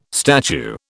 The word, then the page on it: statue: British English